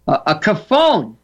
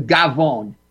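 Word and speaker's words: cafone: A caffone. What's a caffone? That's a phony or embarrassment to himself and others. And another way of saying that is a gavone, which is the slang pronunciation, a gavone.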